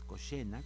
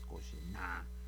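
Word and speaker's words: Kožená: La pronunciación, pues vamos a decir cercana, yo no sé, soy un experto en lengua checa, pero es Cosena, como si tuviéramos una C, no una che, sino una C, Cosena, Cosena, porque tiene un acento en la última, que se hace muy, muy licuadito, no es Cosena, así como lo hacemos en español, sino un poquito más suave.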